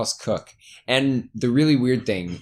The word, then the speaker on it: Coke: My dad's name, K-O-C-H, Cook. Yeah. Married my mom, who is C-O-K-E, also pronounced Cook. – That's so, so bizarre. – It is genuinely, yeah, it's really weird. But yeah, no, that is how I pronounce it.